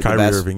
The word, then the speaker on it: Kyrie Irving: Kyrie Irving. I don't know if I'm saying his name right. – Kyrie Irving, yeah.